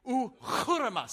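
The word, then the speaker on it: ochyroma: The Greek word for stronghold is pronounced uchurama. You want to try to say that together? Come on, here we go. Ready? Uchurama.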